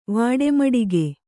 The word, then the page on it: ವಡೆಮೂಡಿಗೆ: vaḍe mūḍige